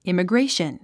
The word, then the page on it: immigration: ìməɡréiʃən; イマグレェィション